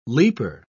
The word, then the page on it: leaper: British English